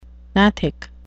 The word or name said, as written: gnathic